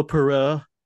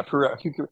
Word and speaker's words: Poirot: The weird thing to me is always that, like, I like Kenneth Branagh, and I've never read the Agatha Christie, Perot. How do you say the guy's last name? – Hercule Poirot. – Yeah, Poirot.